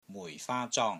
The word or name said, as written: MUI4 FAA1 ZONG1